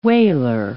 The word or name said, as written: waler